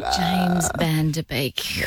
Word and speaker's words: James Van Der Beek: James Van Der Beek.